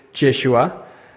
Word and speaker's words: Yeshua: Joseph probably would have pronounced his name Yeshua. The name comes from Hebrew. It's a combination of two words. So we say Jesus, he would have said Yeshua.